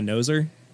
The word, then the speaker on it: Nosr: If I'm going to bed with anything, I take my noser. Which is it's spelled NOSR.